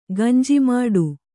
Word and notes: ಗಂಜಿಮಠ: gañji maṭha

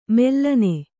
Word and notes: ಮೆಲ್ಲನೆ: mellane

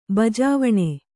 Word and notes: ಬಾಜವಣೆ: bājavaṇe

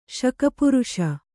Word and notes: ಶಕಪುರುಷ: śaka puruṣa

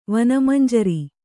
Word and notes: ವನಮಂಜರಿ: vana manjari; Kannada

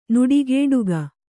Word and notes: ನುಡಿಗೇಡುಗ: Kannada

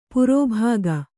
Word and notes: ಪ್ರಭಾಗ: prabhāga

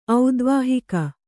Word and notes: ಔದ್ವಾಹಿಕ: kannada

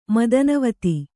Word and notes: ಮದನವತಿ: Kannada

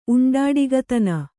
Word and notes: ಉಂಡಾಡಿಗತನ: Kannada

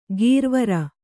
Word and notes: ಗೀರ್ವರ: gīrvara

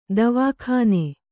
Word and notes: ದವಾಖಾನೆ: davā khāne

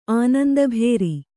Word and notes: ಆನಂದಭೇರಿ: Kannada

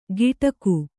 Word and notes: ಗಿಟಕು: giṭaku

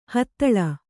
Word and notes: ಹಥಳ: hathaḷa

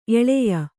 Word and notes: ಎಲ್ಲಾಯಿ: ellāyi

